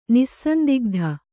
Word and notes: ನಿಸ್ಸಂದಿಗ್ಧ: nissandigdha

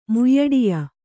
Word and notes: ಮುಯ್ಯಡಿಯ: Kannada

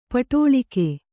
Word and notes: ಪಡಲಗೆ: paḍalage

